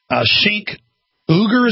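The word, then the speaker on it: Cenk Uygur: Sink Uger is how you pronounce it.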